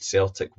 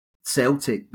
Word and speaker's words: Celtic: This quote is interesting because choosing the name Celtic, really, we should say, rather than Celtic, but over the mist of time, it just became a soft C rather than the hard C.